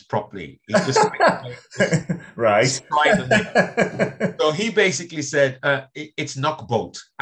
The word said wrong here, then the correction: Knockholt: And then people just laughing. So he said to himself, Knockbolt. And they said, no, it's not Knockbolt. The correct name is Knockholt.